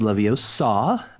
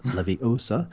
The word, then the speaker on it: Leviosa: So Leviosa is a fan conference for Harry Potter, and it's pronounced Leviosa, not Leviosa, but it's in Las Vegas this year.